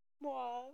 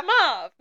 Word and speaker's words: mauve: Mauve. – I forgot how you say it already. Mauve. Just mauve, man. No extra syllables.